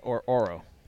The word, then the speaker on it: Ororo: Is it Aurora or Oro? – Ororo. – I always pronounce it Oro. – Oro? – Yeah, O-R-O. You know, I guess you're right. – It's O-R-O-R-O, Aurora.